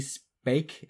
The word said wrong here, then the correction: Spacek: I believe is how you say it. – Spacek. Spacek. Sissy yeah.